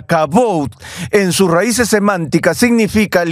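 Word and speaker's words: kabod (כבוד): es la palabra hebrea kabod. A veces se pronuncia kabod.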